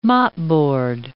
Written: mopboard

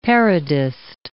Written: parodist

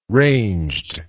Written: ranged

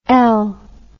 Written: Elle

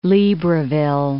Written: Libreville

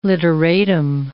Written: literatim

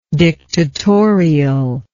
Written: dictatorialness

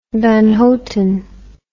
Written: Van Houten

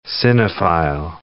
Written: cinephile

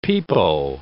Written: pepo

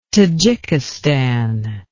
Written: tadzhikistan

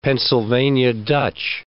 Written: Pennsylvania Dutch

